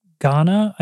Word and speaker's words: Gaana: But in the last 30 days on Gaana, I believe it's pronounced G-A-A-N-A, which is a platform I'd never heard of.